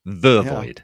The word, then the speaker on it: Vervoid: No, I always want to say Vervoid. Vervoid. Yeah. I believe is the pronunciation.